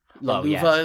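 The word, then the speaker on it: Löwe: Luva is he's pronounced in the game because it's German for lion, and that's an actual word.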